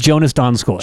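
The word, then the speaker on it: Jonas Donskoi: And it is properly pronounced Jonas Donskoy. – Jonas Donskoy, and it's not even close.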